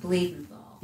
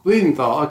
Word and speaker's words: Bluthenthal: It's pronounced Bluthenthal. – Bluthenthal, okay. All right, thank you. – Just the way it's spelled.